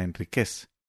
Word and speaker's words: Enríquez: Pronuncio Enríquez como palabra aguda y no como pronunciaríamos, que es Enríquez. Y es que en todos sus libros, y en donde he visto que ella escribe su nombre, Enríquez está sin tilde, con Z.